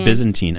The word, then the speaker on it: Byzantine: Uh, Byzantine Empire. – Bi- in, Byzantine Empire.